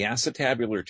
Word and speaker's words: acetabular: The acetabular tear, which you actually pronounced it right, hon. You did good.